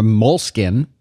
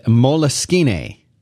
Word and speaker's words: Moleskine: A moleskin, like the skin of a mole. – Yes. – And moleskine, which yes. I assume is the way that people from Syracuse would pronounce it.